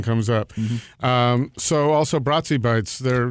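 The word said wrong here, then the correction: Brazi Bites: Brazy Bites. – Brazy Bites. – Yes. – Okay. I didn't. – You're doing the Italian pronunciation, but they're Portuguese.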